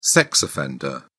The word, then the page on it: sex offender: British English